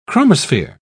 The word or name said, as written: chromosphere